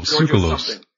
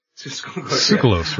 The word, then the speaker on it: Tsoukalos: Oh, Giorgio Tsoukalos. – Tsoukalos, right.